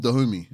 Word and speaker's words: Dahomey: And they're called the Dahomeys. Well, I never know if I'm saying that right. Is it Dahomey? – I think it's the kingdom of Dahomey. – Dahomey, yeah.